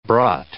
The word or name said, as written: brought